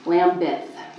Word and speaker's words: Lambeth: Lambeth.